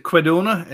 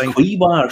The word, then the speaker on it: quinoa: Or is that I think it's quinoa or something. – Is it quinona? Is it not the same? – It's spelled Q-U-I-N-O-A. I think it's quinoa or something.